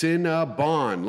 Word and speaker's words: Cinnabon: It's pronounced Cinnabon, like James Bond.